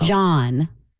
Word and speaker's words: jawn: J-A-W-N. Like yawn, but with a J in front of it. – Oh. – John.